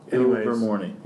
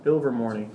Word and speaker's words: Ilvermorny: Ilvermorny. Yeah. – Ilvermorny. – That's right.